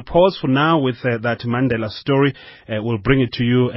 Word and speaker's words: Mandela: Mandela is how it's pronounced, as we heard there.